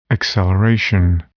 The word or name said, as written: acceleration